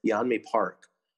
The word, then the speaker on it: Yeonmi Park: uh I think her name is pronounced Yeonmi Park,